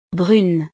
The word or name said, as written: brune